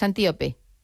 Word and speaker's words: Antíope: ahondas mucho en la relación de Teseo con Antílope. Pero lo he dicho mal el nombre, es Antíope. – Antíope, ¿verdad? – Antíope, Antíope, no, que la he llamado yo como un animal y no puede ser, Antíope.